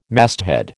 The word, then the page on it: masthead: American English